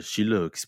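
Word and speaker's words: Scilla: Donc, Chile, qui, s'écrit Chila, c'est aussi une petite ville pareille, construite sur la falaise, qui ressemble un peu aux cinq terres en plus petit.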